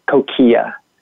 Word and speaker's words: Coquia: The American way to pronounce it is Coquia. – Coquia. – So that's the way that most people pronounce it.